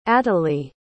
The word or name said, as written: atalie